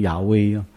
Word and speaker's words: 雅威: 你知道现代的学者都把耶和华的名字，认为他们更正确的读法应该把它读成雅威啊，所以有这个 AH 或者 JAH 等等，这些都有耶和华名字在里面。